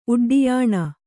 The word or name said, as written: ಉಡ್ಡಿಯಾಣ